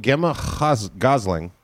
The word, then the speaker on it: Gemma Gosling: This is from Gemma Gosling. – Who? – Management at Big Condo Records. – Wait. Justify that pronunciation. – G-E-M-M-A G-O-S-L-I-N-G. Gemma Gosling.